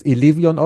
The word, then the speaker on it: Elevion: Du sprichst es Elevion aus, also die deutsche also deutsche Aussprache ist die richtige und nichts Anglizismus da.